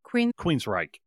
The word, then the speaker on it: Queensrÿche: Queensrÿche.